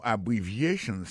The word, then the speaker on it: abbreviations: Abbreviations, accent tonique là, tombé sous troisième syllabe là. Nan mot ça gen quatre syllabes, c'est a bon syllabe, bri c'est deuxième syllabe là, vie c'est troisième syllabe là. Chant, c'est quatrième syllabe là.